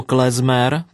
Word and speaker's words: klezmer: Vedle hudby samotné šíří mezi své příznivce i spoustu užitečných detailů, třeba to, že slovo klezmer pochází z hebrejštiny a vyslovuje se se z a nikoli s c.